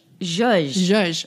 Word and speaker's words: zhuzh: It's the word is spelled Z-H-O-O. – Zhuzh. – Oh, I don't know that word. I don't think I've ever seen it spelled. It's zhuzh? – Zhuzh. It's okay. – A zhuzh.